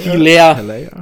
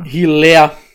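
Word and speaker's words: Helaire: Hilaire. Hilaire, glaube ich. Clyde Edwards Hilaire, so wie ich es immer ausgesprochen gehört habe.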